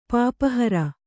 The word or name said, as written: ಪಾಪಹರ